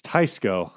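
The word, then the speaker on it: Teisco: It's Tisco, huh? – Yeah, it's Tisco style trim. – Rhymes with heist.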